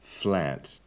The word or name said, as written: flat